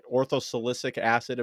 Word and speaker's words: orthosilicic acid: Orthosilicic acid, am I saying that right? It's perfect.